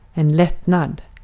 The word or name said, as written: lättnad